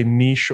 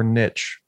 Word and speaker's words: niche: you can say niche or niche, whichever one you want.